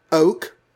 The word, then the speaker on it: oke: I believe oak, O-K-E, pronounced like the tree oak, actually was a slang term, if possibly an outdated one in 1967.